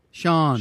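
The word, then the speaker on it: Siân: Shan? Shan? S-I-A-N? Sean. – Sean.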